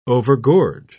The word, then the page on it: overgorge: British English